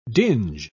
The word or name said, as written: dinge